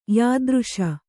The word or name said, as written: ಯಾದೃಶ